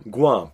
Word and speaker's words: Guam: Guam, euh, je le dis bien, Guam. Guam, pas Guam. Guam. G-U-A-M qui est une petite archipel, c'est une île dans le fond qui euh, appartient comme à, plein de pays.